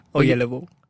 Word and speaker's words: Oyelowo: Oyelowo. Yeah. Oyelowo. – Oyelowo. – Yes. – Oyelowo. – Like that Coldplay song.